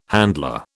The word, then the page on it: handler: British English